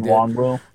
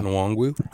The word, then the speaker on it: Nwangwu: how you pronounce his name? I can't do it. – Nwangwu. – Yeah. – Nwangwu.